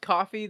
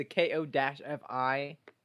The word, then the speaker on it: Ko-fi: We have a, is it pronounced coffee? The K-O-F-I? – Thank you. – Yeah, we have a Ko-fi.